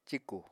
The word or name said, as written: cíkù